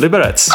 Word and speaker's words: Liberec: Actually, it's the city where I come from, Liberec.